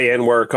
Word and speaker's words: Anwar: Now, Anwar, am I saying that right? – The main guy, Anwar Kongo, yeah.